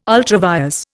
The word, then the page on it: ultra vires: Indian